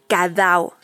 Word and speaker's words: ca dao: Y es por eso que nos parece extraordinario presentarles estos poemas de tradición oral, que se llaman Cadao. Así se escribe C-A-D-A-O, el Cadao, que es poesía de tradición oral de Vietnam.